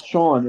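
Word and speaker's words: Siân: It's pronounced like the English S-E-A-N, yeah.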